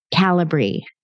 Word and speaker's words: Calibri: where one of her jobs is teaching people how to properly pronounce the company's name, Calibri. It's like calibrate.